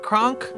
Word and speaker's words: Kronk: Am I saying that right, Kronk? – You got it.